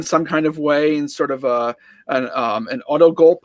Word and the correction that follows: autogolpe: I think I'm saying that right, Andy. That's Spanish. Mm-hmm. Can you help me out there? Is that autogulp? – I don't speak Spanish either, but yeah, autogolpe, I think.